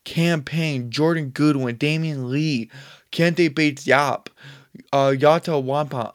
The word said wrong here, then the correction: Watanabe: Yuta Watanabe.